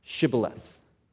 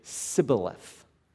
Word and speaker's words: shibboleth: They would say shibboleth. And all the Ephraimites pronounced it Sibboleth, and they could immediately tell they were Ephraimites.